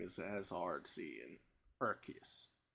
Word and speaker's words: Arceus: It's not Arceus like you've thought your entire life. It's Arceus. It's a hard K.